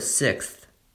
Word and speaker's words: sixth: If you slow down, you can speak all the sounds clearly for example, the word sixth. In this word, there's first an X sound, then an X sound, and finally a TH sound.